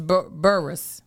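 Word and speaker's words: Burruss: Oh, you did say Burgess. – Burgess is Burris. Burris. Burris.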